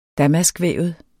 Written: damaskvævet

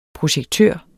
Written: projektør

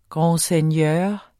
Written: grandseigneur

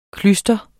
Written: klyster

